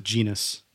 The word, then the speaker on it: genus: You can sign your own Guinness. Is that what it is? Order. – I've always pronounced it genus. – Genus. – Guinness is the beer.